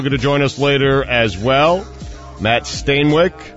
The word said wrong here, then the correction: Stainbrook: Did I say that right? – No. Stainbrook. – Stainbrook. – You put an L in there for some reason.